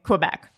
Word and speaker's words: Quebec: Just be fine, guys, with how I'm pronouncing Quebec.